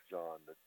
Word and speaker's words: Jeanne: So, there's myself, Glenn, Bob Fisher, Clyde, Sut, and his wife, John. That was the people I knew on the trip. – Sorry, Royal's wife, what was her name again? – It's spelled J-E-A-N-N-E, but pronounced John.